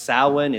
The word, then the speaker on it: Samhain: It's spelled Samhain, but it's pronounced Samhain.